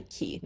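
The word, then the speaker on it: Caye: K, then like you must have, I think it's actually key now that I'm thinking about it.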